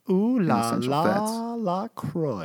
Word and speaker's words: LaCroix: It's pronounced LaCroix, like enjoy. So whenever you're stumbling over, think about that. I want to enjoy my LaCroix.